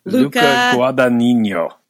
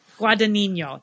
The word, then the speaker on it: Guadagnino: Luca Guadagnino. – Guadagnino.